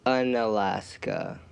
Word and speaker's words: Unalaska: How do you say the name of your city? Because I've read it, but I don't know how to say it. – Unalaska. – It's literally called Unalaska? – Yeah.